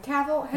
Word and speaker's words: Cavill: The thing is I just saw so much, like, I feel like, Cavalier, is that how you say his name? – Cavill.